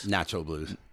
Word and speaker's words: Nacho: Taj Mahal, how do you say this? Nacho Blues? – Nacho Blues. – So almost like nacho.